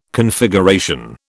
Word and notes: Configuration: British English

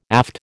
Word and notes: aft: American English

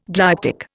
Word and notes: glyptic: Indian

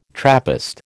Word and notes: Trappist: American English

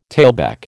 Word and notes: tailback: American English

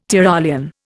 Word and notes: Tyrolean: Indian

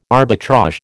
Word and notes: arbitrage: American English

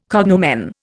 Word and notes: cognomen: Indian

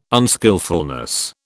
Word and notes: unskilfulness: British English

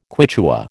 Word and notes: Quichua: American English

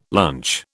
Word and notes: lunch: British English

